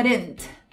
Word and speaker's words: didn't: Então vamos break it down, cortar em algumas partes. Fica de, de, e aí você bota um R em algum lugar e fica didn't, didn't.